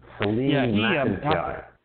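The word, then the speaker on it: Phelim McAleer: Salim Mekletir is the fellow behind the Gosnell documentary/movie/book. Do I have his name right? – Mm-hmm. Salim Mekletir.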